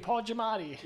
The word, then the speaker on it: Giamatti: I like how you say Giamatti. You say it again. – Giamatti. – Giamatti. – How do you say it? – Paul Giamatti. – Giamatti.